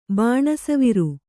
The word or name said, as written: ಬಾಣಸವಿರು